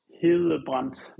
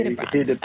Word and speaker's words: Hedebrandt: Okay, so in Danish, it would be Stefan Hillebrandt, which in Danish, you have like this soft pronunciation of a D, but in English, we just say Hillebrandt.